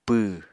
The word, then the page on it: pww: Apatani